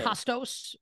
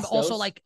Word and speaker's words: Kostos: Kostos was fine. Kostos.